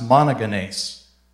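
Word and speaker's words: monogenes (μονογενής): The original Greek is this word, which is pronounced monogenes. I think it's a fun word. Want to give it a try? Okay, the fact that it has the long E sign, that's different when you're talking about Bible languages. It's just trying to distinguish that the two E's have a different sound to them. So it's monogenes.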